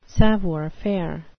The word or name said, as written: savoir-faire